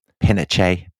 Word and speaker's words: Pinochet: But we respect Chilean pronunciation, yeah. So it will be General Pinochet.